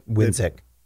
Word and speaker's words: Winzik: That's a major faction in the superiority, and now you see Wixen, right? – Winzik. – Say it again. – Winzik. – Winzik. Thank you. I said it wrong in the recap too.